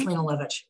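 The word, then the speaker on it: Lene Lovich: Am I saying it right? Lena Lovitch? – What is it? – Lena Lovitch. – Yes, Lena Lovitch.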